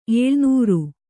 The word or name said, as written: ಎಳೆನೀರು